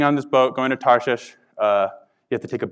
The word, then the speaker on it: Tarshish: So when he said, "I'm actually going to go to a place called Tarshish," it's pronounced Tarshish, I don't have a speech impediment.